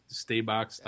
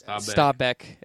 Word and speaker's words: Stabæk: But, you know, whether, I don't know how you pronounce it, it's Stabæk. – Stabæk. It's Stabæk. Yeah.